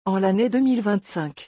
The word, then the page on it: en l'année 2025: French